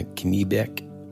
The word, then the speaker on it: Kennebec: But stories that I know to have originated on the Penobscot and the Kennebec, what is that? Am I saying that right? – Mm, sounds good. – I mean, you're the one that likes to correct me, pal. – That sounds great.